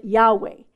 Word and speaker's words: Yahweh: We pronounce it Yahweh.